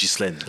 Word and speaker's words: Ghislaine: We just decided that her name is Ghislaine. – That's how you say it.